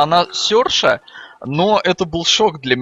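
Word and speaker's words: Saoirse: Оказалось, что на самом деле ее зовут Серша.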